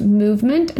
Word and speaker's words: MVMT: It's cute, it's on trend, but I don't like the ones that are $1 million, which is why I like Movement watches, pronounced Movement and spelled M-V-M-T.